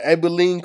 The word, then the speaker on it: Abilene: yeah, Abilene Christian.